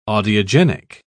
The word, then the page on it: audiogenic: British English